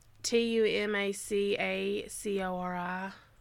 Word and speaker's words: Tumacacori: Um, How do you spell that? – T U M A C A C O R I. Tumakar Tumakakori. – Yeah, Tumaka Tumacacori,